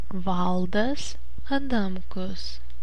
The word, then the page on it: Valdas Adamkus: [ˈvɐ̂ˑɫdɐs ɐˈdɐmˑkʊs]; Lithuanian